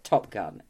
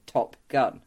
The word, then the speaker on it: Top Gun: It's funny that they don't pronounce it Top Gun in it. – They pronounce it Top Gun. They put the emphasis different than the rest of the world took the emphasis from.